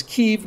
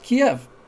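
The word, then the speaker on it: Kyiv: Bill, I noticed you make a special effort to pronounce Kiev as Kiev, not Kiev.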